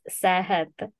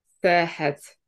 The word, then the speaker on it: Sehhat: So yes, it's correct in reading the name, but just with a little bit of a pronunciation correction, it's Sehhat. – Sehhat.